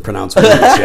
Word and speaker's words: Łódź: L-O-D-Z. – L-O-D-Z is pronounced Łódź, yeah.